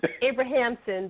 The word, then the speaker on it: Abramson: Jill Abramson. Did I pronounce that right, Jill? – Nobody does. It's okay. It's supposed to be Abrahamson, but we just say Abramson because we're lazy.